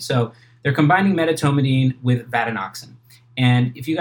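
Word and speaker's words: vatinoxan: Just an aside there, I asked my cousins how to pronounce this drug, because I had never heard of this drug, and you actually pronounced it the way they said to pronounce it, vadenoxin.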